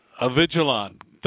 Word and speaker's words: Avigilon: Avigilon.